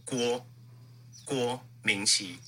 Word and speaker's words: Guo Ming-Qi: So Guo Ming-Qi, you basically take GW, as in like Gwen in Spider-Man. So you take Gwen, drop the EN, add an O. So you go Guo Ming-Qi.